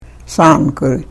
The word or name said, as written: thaankurih